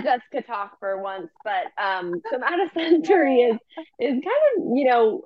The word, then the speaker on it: somatosensory: Somatosensory.